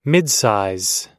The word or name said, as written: midsize